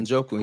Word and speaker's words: Njoku: Njoku.